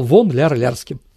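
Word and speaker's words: фон Ляр-Лярский: А потом объединили, потому что по-немецки фон пишется через букву В, фон Лярский. А в России эта буква читается как В. Поэтому он стал вон Ляр Лярским.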